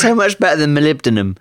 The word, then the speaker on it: molybdenum: Molybdenum. I actually got a message from someone saying, no, you completely screwed that up. This is how you say it. And I think it comes out that it's molybdenum.